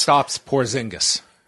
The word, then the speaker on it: Kristaps Porzingis: Kristaps Porzingis.